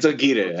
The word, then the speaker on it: Aguirre: It's Aguirre, actually. You never even say it Spanish-wise.